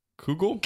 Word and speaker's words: Kugel: I don't know how to say this word. Cudgel? – Kugel? – Kugel.